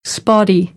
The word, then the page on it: spotty: American English; ɾ